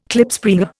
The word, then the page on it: Klipspringer: Indian